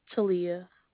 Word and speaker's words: Talia: My name is Talia.